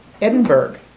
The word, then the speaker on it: Edinburgh: Edinburgh. – Edinburgh is how they say it. Yeah, okay. – True. – It's spelled Edinburgh. – Mm-hmm. Yeah. Okay. But you get the picture. – Dore. D-O-R-R-Y. Dore. – D-O-R-E.